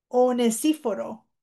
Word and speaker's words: Onesíforo: Tal vez usted no sabe cómo pronunciarlo. Onosíforo. Onesíforo. Onesiphorus. En inglés es onesiphorus o onesíforo.